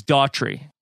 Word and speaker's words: Daughtry: It's Chris Daugherty is the one on Survivor, or Daugherty. Daugherty or Daugherty. – Daugherty. And then this is Daughtry. – Yeah, it's not Daughtry.